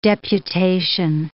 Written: deputation

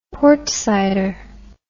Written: portsider

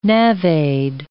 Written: navaid

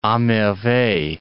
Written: a merveille

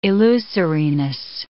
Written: illusoriness

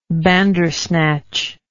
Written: bandersnatch

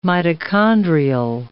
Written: mitochondrial

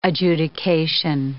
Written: adjudication

